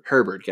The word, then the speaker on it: Herbert: Am I pronouncing that? Or is it Sherbert? – Herbert.